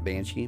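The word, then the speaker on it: bean sí: The word Banshee comes from the Irish word, which is B-E-A-N-S-I, but it's pronounced Banshee, and it translates roughly to a woman of the fairy mounds.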